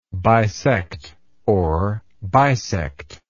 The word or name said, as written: bisectionally